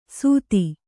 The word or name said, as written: ಸುಧಿ